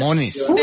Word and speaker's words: Måneskin: Måneskin. – Debí haber quedado con Måneskin, correcto. Es como, sí, como una pronunciación rápida, pero más como...